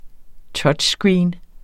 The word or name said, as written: touchscreen